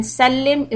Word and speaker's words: sallim: So salli is with a sad and sallim is with a seen. So make sure that your pronunciation is okay.